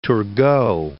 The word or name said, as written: Turgot